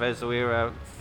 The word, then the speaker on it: Essaouira: I got it right. – Good man. – Essaouira. – No, stop, Essaouira. – Has corrected my pronunciation of Essaouira 50 times. Easily.